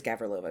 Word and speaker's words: Gavrilova: Gavrilova, because that's how she pronounces it in Australia.